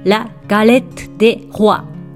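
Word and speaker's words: la galette des rois: このように書いて、ラ・ガレット・デ・ホワと発音します。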